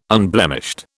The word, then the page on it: unblemished: British English